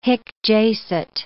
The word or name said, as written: hic jacet